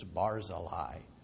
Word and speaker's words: Barzillai: And when this came up as we were going through 2 Samuel, he said, "Where did you ever learn to pronounce it like that?" We said, "Uh-oh." He said, "It's Barzillai."